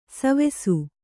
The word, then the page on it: ಸೇವಿಸು: sēvisu